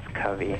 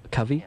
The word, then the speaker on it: Covey: Yes, and technically, not that I much care, it's Covey, like a group of quail. – Oh, Covey? – Yeah.